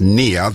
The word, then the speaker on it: Nia: N-I-A, spelled, pronounced near.